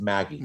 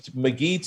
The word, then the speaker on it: McGee: That's interesting because McGee to me would be with a GA. – Exactly. That's why we used to say Maggie, but he pronounced it McGee.